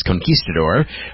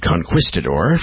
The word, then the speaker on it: Conquistador: That is Conquistador, or as Gary Brooker mispronounces it, Conquistador, from 1972, on the Give Me Back My 70s show.